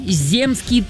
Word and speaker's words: земский: «Земской» слово или «земский». Вот в каком из слов нужно правильно поставить ударение. Выбирайте. – Выбирай. – Земский? – Да! И правильно думаете!